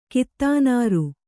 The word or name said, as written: ಕಿತ್ತಾನಾರು